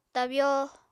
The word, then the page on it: tabyo: Apatani